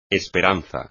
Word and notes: esperanza: espagnol

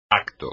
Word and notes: acto: espagnol

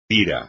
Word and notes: pira: espagnol

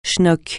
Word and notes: schnock: francais

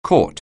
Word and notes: catch on: anglais

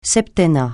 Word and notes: septennat: francais

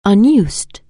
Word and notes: unusable: anglais

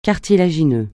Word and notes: cartilagineux: francais

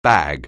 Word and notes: bag: anglais

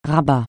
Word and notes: Rabat: francais

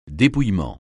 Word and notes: dépouillement: francais

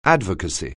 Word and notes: advocacy: anglais

